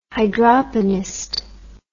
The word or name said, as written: hydroponist